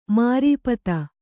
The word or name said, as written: ಮಾರೀಪತ